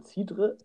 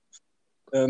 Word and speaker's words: Cidre: Ja, gibt es auch in Frankreich, wird dann Cidre ausgesprochen. Cidre.